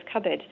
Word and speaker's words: cupboard: I was just thinking about another word such as cupboard. So initially that word would have been pronounced cupboard, but over time we've just got lazy, and now the conventional way of pronouncing that is cupboard.